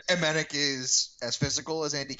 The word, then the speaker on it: Emenike: I also think we could be seeing the debut of Emmanuel Emenike. – Yeah. – Sorry, Emenik. – Emenik, I keep pronouncing it Emenike, but it's not true.